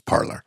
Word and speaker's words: Parler: They're, you could have a Parlay with somebody on Parlor, but they pronounce the name of the platform as Parlor.